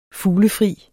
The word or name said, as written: fuglefri